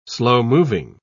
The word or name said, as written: slow-moving